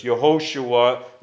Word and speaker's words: Yehoshua: Yehoshua is the Hebrew pronunciation of that.